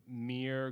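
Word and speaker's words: Muir: You have to go into Whole Foods, and there's this one brand called Mir Glen ketchup. It's M-U-I-R, right? That's how you say it, like Mir Woods.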